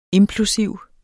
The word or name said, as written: implosiv